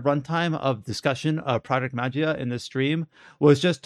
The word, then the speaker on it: Magia: And that was a project called Project Magia, M-A-G-I-A. Project Magia is how I'm going to pronounce it.